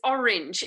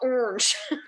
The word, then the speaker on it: orange: My hand was basically up the entire time, but particularly with orange, orange, I'm like, there are two syllables. It's orange. It's not orange.